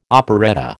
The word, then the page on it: operetta: American English